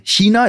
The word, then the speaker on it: China: Ich wäre zum Beispiel sehr sauer bei ähm, ähm, China, ist das richtig ausgesprochen? Ja. Einige ja. sagen China, das ist, das ist absolut. Falsch, ja. da werde ich aggressiv. Ja. – Ähm, richtig wäre auch noch China.